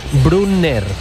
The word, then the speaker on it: Brunner: Paris Brunner, con dos N's, Brunner.